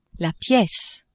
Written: piece

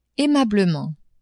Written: aimablement

